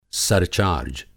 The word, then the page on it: सरचार्ज: سرچارج